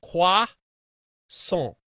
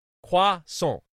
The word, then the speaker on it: croissant: So we're sitting around and she says, it's croissant, no T, croissant, croissant.